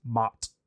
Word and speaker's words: Maat: goddess Maat. I probably mispronounced that. I apologize. – I think that's right.